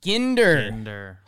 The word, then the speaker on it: Ginder: Update, it's Henry Ginder. – Ginder. – Hard G.